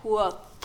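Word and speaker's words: Kvothe: A ver, Lady Datos en la, en la página de Patrick Rothfuss dice que se dice quoth. Quoth.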